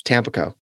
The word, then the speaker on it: Tampico: I said it right. It's Tampico, Illinois. If you're from the area, you know I'm right.